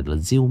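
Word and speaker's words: ZIL: Ziu, những người ta gọi là Ziu, đọc là Ziu mới đúng.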